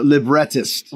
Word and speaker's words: librettist: It's a librettist.